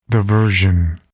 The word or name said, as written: diversion